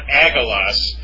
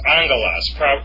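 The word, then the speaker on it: angelos (ἄγγελος): So this isn't angelos, it's angelos. It's not agalos, it's angelos. Pardon me, angelos, meaning angel. Agros, meaning field.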